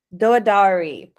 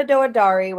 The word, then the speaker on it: Douedari: Douedari. Perfect. So we have Sara Douedari with us today.